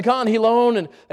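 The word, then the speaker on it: Canjilón: We get up to Khan Hilone, and that's spelled with a J, all right? C-A-N-J, Khan Hilone.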